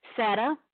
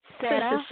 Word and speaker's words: Seta: Sada. – Say it again. – Sada. – Sada. – Uh-huh. – How do you spell that? – S-E-T-A.